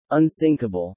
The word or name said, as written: unsinkable